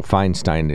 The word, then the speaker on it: Feinstein: Feinstein is how you say the name, first of all.